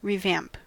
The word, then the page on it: revamp: American English